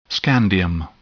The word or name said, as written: scandium